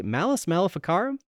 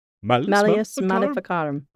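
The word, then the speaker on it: Malleus Maleficarum: I don't know if I said that right. Malus Maleficarum? – Yeah. – Malus Maleficarum. – Oh, Malus Maleficarum.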